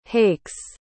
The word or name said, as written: hakes